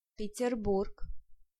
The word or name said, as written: Peterburg